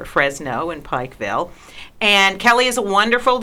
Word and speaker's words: Pikeville: Can we say Pikeville? – Mm-hmm. – Pikeville? – You can say it any way you like. – How do you say it, Marcy? – Yeah, these days I say Pikeville. I'm sure growing up I said Pikeville.